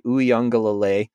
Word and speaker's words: Uiagalelei: is the Clemson quarterback DJ Uiagalelei.